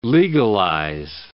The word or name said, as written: legalize